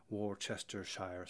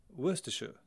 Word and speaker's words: Worcestershire: For example, if you ever grew up with a bottle of sauce in your fridge and it said Worcestershire sauce, you probably learned at some point that that wasn't the correct way to pronounce it. In fact, in England, all over England, that would be called Worcestershire sauce.